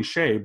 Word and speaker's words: Hsieh: And so anyway, if you are ever interested in anything, it's Tony Hsieh, but it's spelled S-H-E-I-H, and he's the founder of Zappos.